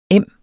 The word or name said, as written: em